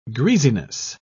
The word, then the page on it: greasiness: British English